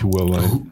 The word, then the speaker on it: Huawei: Sagt man das so? – Eigentlich Huawei.